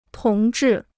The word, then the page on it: 同志: tóng zhì